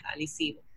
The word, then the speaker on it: Arecibo: Por ejemplo, Arecibo no se pronuncia Arecibo sino es alisivo.